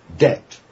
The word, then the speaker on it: debt: You should pronounce debt as D-E-B-T, says Holofernes, and he's horrified to hear Don Armado leave out the B.